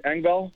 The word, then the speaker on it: Engvall: But I'm talking like Kerfoot and Engvall. That's how you say his name, right? Engvall? – Yeah. Mm-hmm.